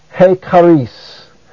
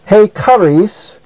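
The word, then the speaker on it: he charis (ἡ χάρις): Also notice that he is a proclitic. That is, it goes so closely with the word that follows as to have no accent of its own. Therefore, it is pronounced he charis.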